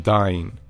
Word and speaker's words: Dyn: In the most recent case, the attack was on a New Hampshire-based company called DYN, pronounced Dyne.